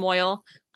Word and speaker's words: mohel: A lot of areas have a rabbi, like we have a moyle, I think it's pronounced how it's pronounced, M-O-H-E-L, yeah. but it's pronounced mm-hmm. moyle.